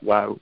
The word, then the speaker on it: WAU: And so the acronym then is WAU, and we phonetically pronounce that WOW.